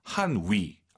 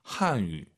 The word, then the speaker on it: 汉语: 한위. 알아듣지만 틀린 발음이에요. 입술을 동그랗게 말아서 쭉 내밀어 보세요. 한유 하시면 좋을 것 같아요. 삼성이 마지막 글자면 올리지 마시고요.